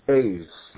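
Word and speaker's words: Aes (עז): Now, the word there used for goat is the word Aes, Ayin Zion is how it's spelled, Aes.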